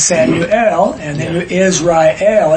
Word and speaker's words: Israel: And so it's not Israel, not two syllables, it's Israel, three syllables.